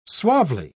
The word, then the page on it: suavely: British English